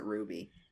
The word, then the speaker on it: RWBY: I think you do pronounce it Ruby.